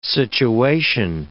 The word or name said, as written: situation